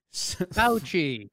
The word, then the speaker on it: Fauci: Fauci. – Fauci? – Like the only doctor being talked about. – Yeah. I know, but I haven't heard it. I've only read it. Dr. Fauci says he doesn't think Americans should ever shake hands again to prevent the spread of coronavirus.